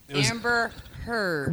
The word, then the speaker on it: Heard: Amber Heard. – Which rhymes with turd.